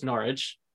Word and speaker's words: Norwich: But then, you know, you guys get me to mispronounce your city all the time on every single podcast episode, which I now know is pronounced Norwich.